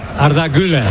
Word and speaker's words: Güler: Viene Arda Aguilar. Lo digo bien, Melchor, Arda Aguilar. – Arda Güler.